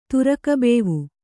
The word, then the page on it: ತುರುಕ ಬೇವು: turuka bēvu